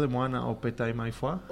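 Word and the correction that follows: Opetaia Foa'i: O es Opetaya Foa. – Opetaya Foa.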